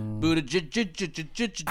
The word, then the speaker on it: Buttigieg: Pete Buttigieg. – How do you say his name? – Buttigieg. – Buttigieg.